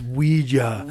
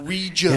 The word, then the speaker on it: Ouija: No, and it's pronounced Ouija. – Ouija. – And it comes from the German and French. – Oui and ya.